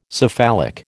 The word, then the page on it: cephalic: American English